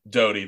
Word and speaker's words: Doty: Doty. – That actually reads better. Luke Doty. Do you have a spelling? – Do you have a spelling on that? – D-O-T-Y. – Okay. I would have said Doty as well.